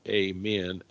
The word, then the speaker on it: Amun: The Egyptian god Amun, apparently is pronounced amen, A-M-E-N, was one of the few Egyptian gods that his form was totally human.